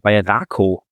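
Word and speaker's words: Raków: Er ist 21 Jahre jung und hat bei Rakow, wird der Verein ausgesprochen, in Polen gespielt, also eigentlich so ein bisschen unter Radar und jetzt vielleicht nicht so die Top-Kategorie.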